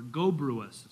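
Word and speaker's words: Gobryas: The Greeks tell us this general's name was Gobrius, or Gobruus is how you would pronounce it.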